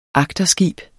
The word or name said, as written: agterskib